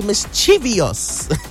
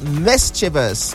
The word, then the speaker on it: mischievous: We say it's mischievous, but that's not the way to say the word. But because you're calling, this is the second time, let me quickly give the correct pronunciation right now. So the word is correctly pronounced mischievous.